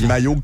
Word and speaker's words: Maillot: Non, c'est Maillot comme de bain.